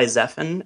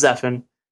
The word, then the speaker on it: Zephan: So I actually go by Zephan, actually. But the original, if you read it in Hebrew, it's usually pronounced Zephaniah. And so every now and then people kind of like throw this French accent on it. But it's Zephan.